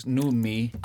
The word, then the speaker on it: Noomi: Now, you were pronouncing the name of the lead actress correctly. I wasn't. It's Noomi. – Oh, yeah. I was just consulting with Wikipedia. It's supposed to be Noomi Rapace.